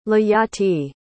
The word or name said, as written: leyati